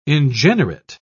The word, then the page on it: ingenerate: British English